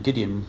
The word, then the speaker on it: Gideon: Gideon rhymes with Midian.